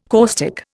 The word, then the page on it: caustic: Indian